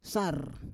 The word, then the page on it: sar: Roon